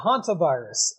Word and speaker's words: hantavirus: But luckily talking about the hantavirus. Is that how you pronounce it? – Yeah, hantavirus.